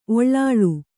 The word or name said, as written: ಒಳ್ಳಾಳ್